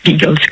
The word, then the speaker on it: Beagles: Mais je pense que naturellement la prononciation la meilleure, c'est de le prononcer à l'anglaise, puisque c'est un chien anglais, Beagles comme les Beatles.